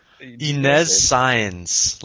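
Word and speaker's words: Inés Sainz: Inez Sainz, like Olmedo Sainz, but Olmedo's much hotter.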